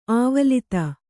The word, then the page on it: ಆವಲಿತ: āvalita